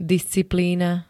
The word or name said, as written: disciplína